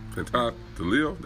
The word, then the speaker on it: Pantaleo: Died in the borough of New York City after Officer Daniel Palat Alio. Pantaleo, that's how you say his name, I guess.